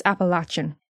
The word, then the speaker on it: Appalachian: I think it's Appalachian. I've heard both pronunciations, but I think Appalachian is the correct one.